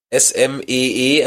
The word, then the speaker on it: Smee: Zeitgleich haben sie aber auch noch in dem Raumschiff den Roboter SM-33 geweckt, der, und jetzt gehen wir mal in Leadspeak, SMEE, also 33, spricht sich dann wie aus? Smee? – Ah, ja.